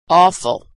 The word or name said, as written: awful